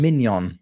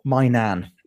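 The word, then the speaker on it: Maignan: Mike Mignon, or my nan, depending on pronunciations.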